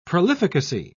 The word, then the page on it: prolificacy: British English